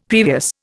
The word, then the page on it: previous: Indian